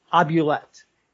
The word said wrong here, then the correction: oubliette: Oubliette, I think, is the vocab.